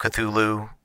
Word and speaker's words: Cthulhu: What we are doing is on Saturday nights, we will be running Masks of Nyarlathotep, the very long famed Call of Cthulhu, that is the phonetic way to pronounce it, campaign.